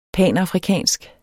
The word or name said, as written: panafrikansk